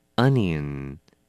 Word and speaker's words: onion: Onion. 名词洋葱。这个字呢要稍微小心它的发音了，不能念成太凹，是 onion. 要轻一点，不是 onion, 是 Onion, onion.